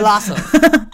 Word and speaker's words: Vlasov: It's V-L-A-S-O-V.